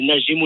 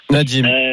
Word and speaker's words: Nadim: Je prononce ton prénom correctement? – Oui, c'est Nadim ou Nadim, comme vous voulez. – Ah, tu préfères quoi, toi? – Nadim.